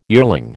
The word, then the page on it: yearling: American English